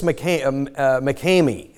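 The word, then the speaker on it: Makemie: Francis McCamey is the way to pronounce the name.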